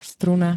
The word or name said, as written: struna